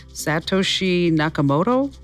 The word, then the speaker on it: Satoshi Nakamoto: Satoshi Nakamoto? – Yep.